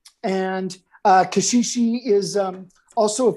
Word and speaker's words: caxixi: so you're holding them on the body so that you get because um, on these these shakers so these shakers are called caxixi these particular ones, which is a basket shaker, um, and it's got a piece of hard plant on the bottom.